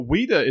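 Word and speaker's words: Ouida: Wida and Daisy. – So, Wida is actually spelled O-U-I-D-A. I had to go to Google Translate to figure out how to pronounce it.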